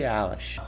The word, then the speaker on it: Eilish: Billie Eilish.